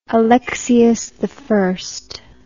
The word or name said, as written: alexius i